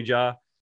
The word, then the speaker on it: Jaa: Is that how you pronounce it, Ja? – Yeah.